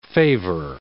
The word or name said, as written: favor